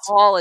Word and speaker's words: Haul: And it's Haul as in H A U L, rhymes with Paul.